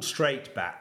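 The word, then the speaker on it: Str8bat: And it's called Straight Bat, the technology. It's spelled STR, then the number 8, bat.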